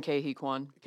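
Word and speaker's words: Ke Huy Quan: Ki Hu Kuei, I always get his name wrong. – I've been hearing Ke-Hee Kwan. – Ke-Hee Kwan. Thank you.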